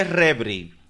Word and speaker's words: Revry: se escribe Rebri. Se escribe Rebri. R-E-V-R-Y.